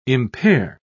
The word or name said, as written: impair